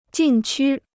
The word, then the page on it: 禁区: jìn qū